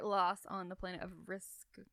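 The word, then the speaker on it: Rizq: There's no right way to pronounce this. He made it up. Risk. It's R-I-Z-Q.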